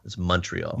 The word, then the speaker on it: Montreal: It's V-I-V-A-F-R-E-I. And he lives in Montreal, Canada, in Quebec. – Montreal. It's Montreal.